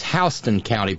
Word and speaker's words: Houston: It's Houston County, by the way, which is also spelled the same way as Houston, but it's Houston. It's Houston in New York City, and it's Houston in Georgia.